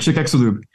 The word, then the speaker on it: Chicxulub: So you've got a chapter on surviving the Chicxulub asteroid. How do you say that? – It's a tough word. Chicxulub. – Chicxulub. Okay, asteroid. I don't know about it, but 66.5 million years ago.